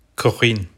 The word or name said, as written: kuxîn